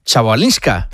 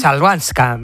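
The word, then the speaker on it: Chwalińska: Joder, ¿cómo se dice la es complicada, ¿eh? Chabalinska. – Chalbanska.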